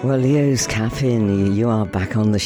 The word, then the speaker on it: LeosCappin: That was Lost You, and it's Leo, L-O, I'm going to get this right, hang on a minute, L-E-O-S, and Cappin, C-A-P-P-I-N. LeosCappin is how you pronounce it, so I'm told.